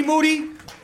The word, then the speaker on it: Moody: G Moody. Last name rhymes with duty.